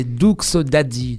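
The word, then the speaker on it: Doox Daddy: Et donc mon nom d'artiste, c'est Doux Daddy. Donc ça s'écrit D-O-O-X et plus loin Daddy, D-A-D-D-Y. Donc Daddy, ça veut dire papa.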